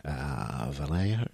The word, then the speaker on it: Vallejo: It was using a couple of Vallejo.